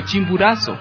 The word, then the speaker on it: Chimborazo: nació en la cercanía geográfica y lingüística al Chimborazo, palabra que antes era pronunciado como Chimburazo.